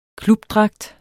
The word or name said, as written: klubdragt